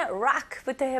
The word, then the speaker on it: rock: 네, 일단은 rock부터 해볼게요. 이거는 R로 시작하잖아요. 그래서 lock라고 하면 잠그다라고 되거든요.